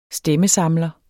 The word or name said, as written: stemmesamler